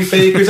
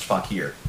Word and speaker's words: fakir: Although, it's not pronounced faker, it's pronounced fakir.